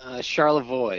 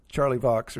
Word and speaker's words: Charlevoix: Charlevoix is the official pronunciation.